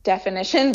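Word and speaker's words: Deafinition: There's actually an app that you can download from the App Store called Definition, like spelled like the word definition, but D-E-A-F, so Definition.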